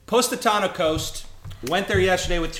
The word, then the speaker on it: Positano: No, I don't want to get pronounced Positano.